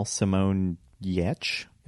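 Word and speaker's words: Simone Giertz: She mentioned her pal Simone Yetch is how she pronounces it, but it's spelled like Gertz, helped her disassemble it to move it.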